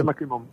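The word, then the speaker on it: MacCrimmon: Es que el nombre no sé si lo digo bien, es Macrimón, creo que es. – Sí, Macrimón.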